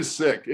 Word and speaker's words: Sikh: It's actually Sikh. In Punjabi, in Punjabi, they pronounce it Sikh the same way we would say, you know, I got sick, whatever.